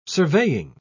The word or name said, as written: surveying